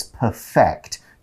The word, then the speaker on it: perfect: When you use it as a verb, it's perfect. You put the stress on the second syllable.